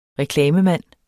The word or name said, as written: reklamemand